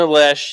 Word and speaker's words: Loesch: Uh, Dana Loesch, yeah, she she's a former NRA, she, yeah.